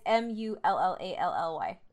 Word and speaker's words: Mullally: Yeah, it's Mullally.